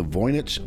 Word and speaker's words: Voynich: All I wanted to just tell you something that I learned about the other day called the Voynich manuscript. – And, say that again. Spell that for me. – A V-O-Y-N-I-C-H. – Voynich. – Voynich manuscript.